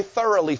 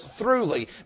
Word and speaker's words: throughly: I used to always say thoroughly furnished, but the Bible doesn't say thorough. It says throughly, meaning furnished throughout, from head to toe, from top to bottom.